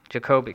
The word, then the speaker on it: Jacoby: Jacoby. – Thank you. Okay, long O.